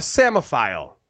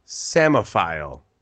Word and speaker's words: psammophile: Samophile. – Samophile. S. I was going to say A. Was it an A? – No. – Oh, okay. Then no. – It's a P. P-S. – Oh, fuck. – P-S-A-M-M-O-P-H-I-L-E.